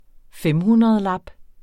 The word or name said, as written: femhundredlap